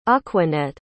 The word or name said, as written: aquanette